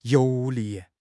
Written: 油腻

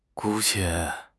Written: 姑且

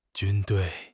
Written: 军队

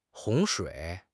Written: 洪水

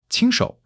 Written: 亲手

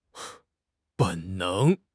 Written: 本能

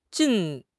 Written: jin4

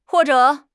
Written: huo4 zhe3

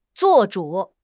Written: 做主

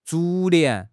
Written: 逐年

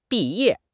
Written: bi4 ye4

